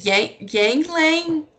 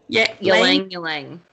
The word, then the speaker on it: ylang-ylang: And I think I'm saying it wrong. Is it Yang Lang? – Ylang-ylang.